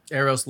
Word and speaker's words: Eros: We now have, how do you pronounce it? Eros? – Eros Love Shop.